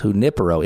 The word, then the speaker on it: Junipero: I've been calling him Junipero, and it's Junipero, emphasis on the I. – Yeah, in the Spanish, the accent is on the I.